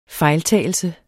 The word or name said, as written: fejltagelse